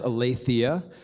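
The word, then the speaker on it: aletheia (ἀλήθεια): It's pronounced aletheia.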